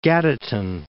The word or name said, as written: Gaditan